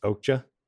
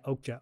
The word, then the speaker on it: Okja: It's pronounced Okja. – Yeah, Okja. O-K-J-A. You might that's how they pronounce it.